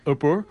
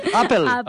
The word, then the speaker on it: Apple: La marca de ordenadores, Upper. – Apple.